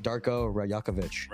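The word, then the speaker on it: Darko Rajaković: So I can't pronounce their coach's name, but Darko Rajakovic. – Rajakovic.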